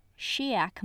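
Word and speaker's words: Sidheag: She made friends with, in this audiobook pronounced correctly, Shiak Makan, Sophronia Temenik, and Demity Plumley Tainmont, Pilliver's older sister. You know, it's really hard for me to say Shiak, even though it's correct, because I want to say it.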